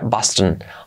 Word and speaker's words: Boston: Boston, fijaos cómo se pronuncia. Boston.